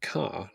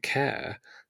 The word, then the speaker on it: Kerr: Now, how would you pronounce K-E-R-R? – Kerr. – And I've met people who do, but he pronounced it Carr, like Deborah Carr, the noted actress. But Laura, being Northern Irish, pronounced it Kerr. And they've basically now compromised on pronouncing it Care.